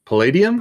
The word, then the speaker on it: Palladium: there's this element called Paladin that he has to use. Is it Paladin? Is that how you pronounce it? – Palladium? – Palladium. Thank you.